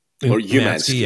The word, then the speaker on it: Umansky: Umansky.